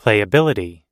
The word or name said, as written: playability